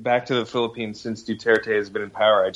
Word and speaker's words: Duterte: How do you pronounce his name? Duterte? Is that it? – I think it's Duterte, yeah.